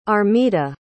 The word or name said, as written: armida